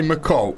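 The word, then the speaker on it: MacColl: Yeah, say McCall, and you'll get it right.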